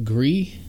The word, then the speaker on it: Gris: I think it's actually pronounced Gris. I think it's French.